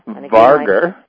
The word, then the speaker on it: wagr: it's V-A-R-G-R. – You're pronouncing it very well, Donna. – All right. And then the German Wager, or W-A-G-R. – Varger. – Varger.